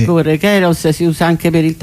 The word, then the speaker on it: Kairos: A parte la pronuncia sarebbe Kairos, ma insomma vabbè.